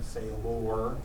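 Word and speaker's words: Seiler: And I must say this because notice how my last name is spelled. If you look on the sign up page, they screwed it up. They mean say lore.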